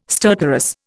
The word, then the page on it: stertorous: Indian